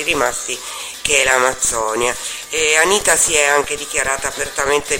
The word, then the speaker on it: Anitta: E c'ha due T. – Ma se ne pronuncia sempre una. Allora, Anitta è veramente famosa cantante e ballerina brasiliana,